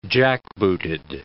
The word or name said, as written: jackbooted